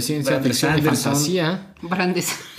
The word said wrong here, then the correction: Brandon Sanderson: Pues, digo, Brandon Sanderson. – Nunca lo digo bien.